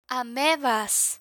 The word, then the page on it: amebas: spanish